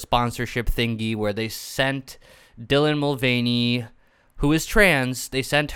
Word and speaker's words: Dylan Mulvaney: So basically, what happened was Dylan Mulvaney, is that how you pronounce her name? I hope so. – Yes, it is. It is.